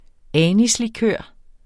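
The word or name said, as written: anislikør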